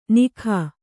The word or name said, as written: ನಿಘ